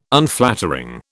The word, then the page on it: Unflattering: British English